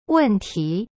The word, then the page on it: 问题: wen ti